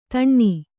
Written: ಧೇನು

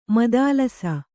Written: ಮದಾಲಸ

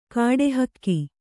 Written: ಕಡುಹಾಕು

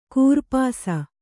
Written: ಕೂರ್ಪಾಸ

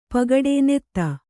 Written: ಪೋಗೊಡು

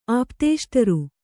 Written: ಆಪ್ತೇಷ್ಟರು

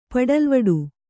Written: ಪಡಲ್ವಡು

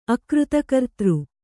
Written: ಅಕೃತಕರ್ತೃ

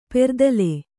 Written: ಪೆರೆದಲೆ

